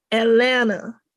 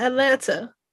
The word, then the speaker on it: Atlanta: So, but true be like, if you know, it's Atlanta. And how I know she said it right, because she rhymed it with Savannah.